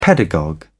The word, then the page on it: pedagogue: British English